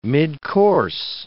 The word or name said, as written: midcourse